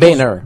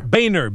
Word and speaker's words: Boehner: Boehner. – Boehner.